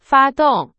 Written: 发动

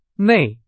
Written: 内

